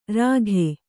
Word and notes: ರಾಖಿ: kannada

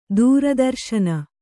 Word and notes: ದೂರದರ್ಶಿನಿ: dūra darśini

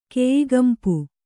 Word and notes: ಕೆಯಿಗಂಪು: kannada

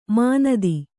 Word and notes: ಮಾನಿತ: mānita

